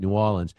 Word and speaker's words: New Orleans: And, you know, even reporters in New Orleans, you got to say it right, New Orleans,